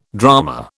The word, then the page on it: drama: British English